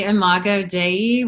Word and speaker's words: Imago Dei: The Imago Dei, which is the God image within, mm-hmm.